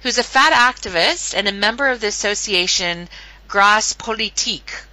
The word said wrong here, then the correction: Gras Politique: How do I pronounce the name of the group? – It's pronounced Gras Politique. – Gras Politique. Oh, that's so beautiful in your accent.